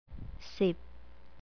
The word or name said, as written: sip